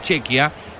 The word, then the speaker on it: Chequia: Es el segundo turno del día, es el segundo partido de la jornada, porque más temprano Chequia, se dice así, es Chequia, igualó 1-1 con Sudáfrica en un partido con un resultado algo sorpresivo.